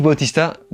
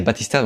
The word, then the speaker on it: Bautista: Dave Bautista, Batista, enfin comment je... Quand j'étais petit, on disait Batista, donc je sais pas si on prononce Batista ou Bautista, bref.